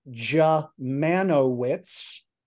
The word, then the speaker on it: Dziemianowicz: And the American pronunciation of that ghastly last name, which makes me Stefan the Unspeakable or Stefan the Unpronounceable, is Jamanowicz.